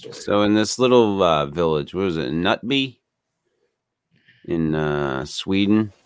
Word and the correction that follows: Knutby: I listened to some actually Swedish people talking about the case, and they called it Knutby. I'm sorry, Knutby. – Knutby. – They pronounced it. – Okay. Well, that's better. Okay. That's much better.